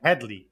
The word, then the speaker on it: Headey: Get her name right. It's not Headey. It's Headey.